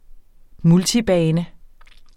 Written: multibane

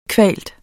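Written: GRÅDKVALT

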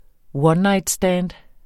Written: one-night stand

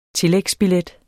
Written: tillægsbillet

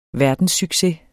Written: verdenssucces

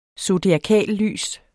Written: zodiakallys

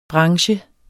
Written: branche

